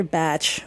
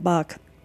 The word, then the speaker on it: Bach: Basically what it was Dr. Bach, which is the correct pronunciation, but in this country we all call it Bach, was a doctor in the turn of the century in the 1900s in the UK.